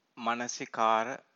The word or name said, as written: manasikara